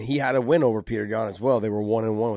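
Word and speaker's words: Petr Yan: He also had a win over Piotr, however you want to say his name. It's Peter Yan, okay, people? – There you go.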